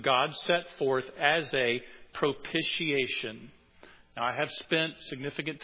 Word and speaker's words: propitiation: Well, somebody looked it up and said it's propitiation. Think initiation, right? Initiation. It sounds just like that.